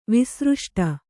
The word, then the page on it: ವಿಸೃಷ್ಟ: visřṣṭha